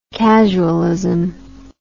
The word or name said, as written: casualism